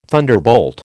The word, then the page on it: thunderbolt: American English